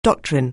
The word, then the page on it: doctrine: anglais